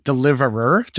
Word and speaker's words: Deliverr: Well, I'm going to pronounce it Deliverer just for fun because there's two R's.